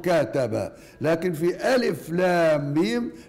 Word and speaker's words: الم: يبقى إذن حينما كتبت المصحف بهذه الصورة فهي مكتوبة بالحروف، ولكننا حينما ننطقها ننطقها بأسمائها، ننطقها بأسماء، لا نقول ألم، لا نقرأها ألم، وإنما نقرأها بأسمائها ألف لام ميم.